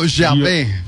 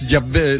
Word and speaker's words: Yahvé: Sería como Yahvé. Yahvé. No, no, y además la W es Yahvé.